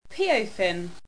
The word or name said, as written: Peophin